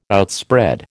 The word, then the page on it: outspread: American English